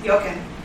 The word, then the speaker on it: Jochen: CEO Matt Levitich stepped down, replaced on an acting basis by board member How do you say that? Yoshin? Yoshin? – Oh, you guys are better. – It's J-O-C-H-E-N. – Jochen? – Jochen. I'd say Jochen Zeitz.